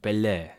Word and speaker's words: Pelé: Ik vind het mooi dat je Pelé op zijn Italiaans uitspreekt. Want dit is natuurlijk zo'n naam die in Nederland gewoon op zijn Pelle. Nederlands wordt uitgesproken. Ja, dus dan doe ik het ook maar natuurlijk, zoals je me kent. Maar inderdaad, in het Italiaanse commentaar is het Pelé. En dat is toch wel wat anders dan dat we in Nederland Pelle zeggen.